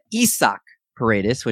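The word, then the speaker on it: Isaac Paredes: Taking a look at Isak Paredes, which I found out that's the proper way to pronounce it, according to our buddy Britton Allen.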